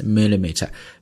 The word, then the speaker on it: millimeter: 毫米呢叫 millimeter 怎么拼呢 m-i-l-l-i-m-e-t-r-e millimeter 这是毫米那么这个厘米是 cm 那就是 centimeter so millimeter or centimeter 这两个单位当然可以写成 cm 也可以写成 mm so next time when you see mm you will directly pronounce millimeter